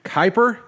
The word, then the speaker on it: Kuiper: So, this is Maggie Kuiper. Did I say that right? – You did. Rhymes with hyper.